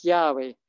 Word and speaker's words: Yahweh: And so, and it's to pronounce it, it's Yahweh.